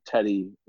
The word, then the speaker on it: Teddy: My Twitter is my name, Teddy Willsey, T-E-D-D-Y, and then W-I-L-L-S-E-Y.